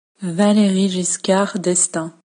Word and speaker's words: Valéry Giscard d'Estaing: To get back to President Valéry Giscard d'Estaing, here is the correct pronunciation by a native French speaker. – Valéry Giscard d'Estaing.